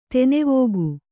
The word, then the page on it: ದಾನವಿಕ: kannada